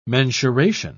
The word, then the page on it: mensuration: British English